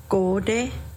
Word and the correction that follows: gonde: And going back to eel, gonde. – Gonde.